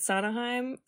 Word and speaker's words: Sondheim: And Stephen Sana, how do you say it? Sondheim? The Broadway guy. – Yes.